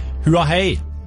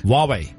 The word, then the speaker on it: Huawei: Jeg tror uttalen Huawei mer enn wei wei. – Åja, oh, så A først. – Huawei. – Huawei. – Ja, så ikke hua hei, Huawei.